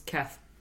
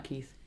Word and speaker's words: Keth: Also, it's Keth, not Keith. – Sorry. – It's okay. – I'll write that phonetically. – T-H, not an F-F? – Correct, okay. – Yes. – Oh. – Yeah. – Oh, yeah. – Yeah, K-E-T-H.